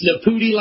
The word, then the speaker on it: Nepute: It's Nepute like booty, but you got everything else right.